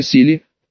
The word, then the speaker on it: Сели: Именно, дорогая Сели, подчеркнул я ударение на последнем слоге.